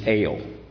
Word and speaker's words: Eu: The first little word is ale. We pronounce it's the EL, but we, I mean EU, but we pronounce it with EL sound. So ale. Ale.